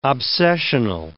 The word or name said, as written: obsessional